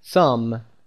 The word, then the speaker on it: thumb: نکته بعدیش هم این هست که بی تلفظ نمیشه در این کلمه. ثام.